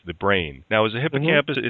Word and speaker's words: hippocampus: And, of course, you mentioned the hippocampus. Did I say it right? – Hippocampus, yeah. – Hippocampus. So the O is kind of like not emphasized.